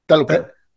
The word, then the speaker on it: Talokan: and they get taken essentially to Talokan? – Talokan.